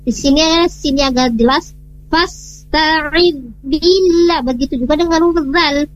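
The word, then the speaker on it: fasta'id (فاستعذ): Perbaikan di huruf sinnya, di sana perlu perbaikannya di kejelasan di huruf sin. Tidak fasta'id, tapi fasta'id. Nah, jelas ya pengucapannya huruf sin. Setelah itu baru pindah huruf ta-nya, fasta. Jadi enggak fasta'id, fasta'id. Jadi fasta'id billah.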